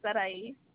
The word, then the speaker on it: Sarai: Sarai. S-A-R-A-I.